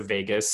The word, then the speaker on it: vagus: If you're on this call and you don't really know that, it's spelled V-A-G-U-S, not like Las Vegas, but vagus in Latin, which means wanderer.